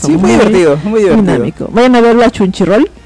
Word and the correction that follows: Crunchyroll: Crunchyroll. Exacto, Crunchyroll.